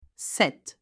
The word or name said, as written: 7